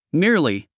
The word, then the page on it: merely: míə r li; ミィアリィ